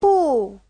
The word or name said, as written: bu4